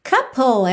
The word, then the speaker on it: couple: Couple, essa é uma palavra importante também é, para você aprender, porque a escrita não bate perfeitamente com o som, se você ainda não tem muita intimidade com o inglês. Então, essa palavra, que significa casal, se escreve C-O-U-P-L-E. C-O-U-P-L-E. Mas a pronúncia é couple, couple, couple.